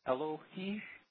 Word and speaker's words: Eloi: Elohi. And that's what that E-L-O-I pronounced. It's Elohi.